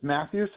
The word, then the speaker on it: Matthew: It's Matthew, so MATTHEW.